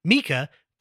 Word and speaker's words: Mika: And it's like literally the opening line was like Mika, pronounced M-E-E-K-A.